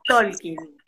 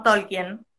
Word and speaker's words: Tolkien: Y ahí en la película él dice que se, pronuncia Tolkien. – porque acá siempre decimos Tolkien.